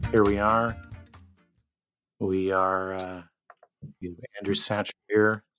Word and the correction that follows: Sacker: It's Sacker. The H is like a K.